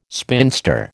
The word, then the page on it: spinster: American English